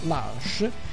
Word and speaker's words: Lush: La prima offerta ci è arrivata, ci è stata segnalata direttamente dalla Lush, scritta Lush, ma si pronuncia Lush,